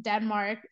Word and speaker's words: Denmark: I just say Denmark, much easier, much simpler.